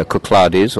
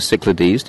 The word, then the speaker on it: Cyclades: It ticks all the boxes that you would expect of a seaside town in the Cyclades, or the Cyclades, depending on your pronunciation.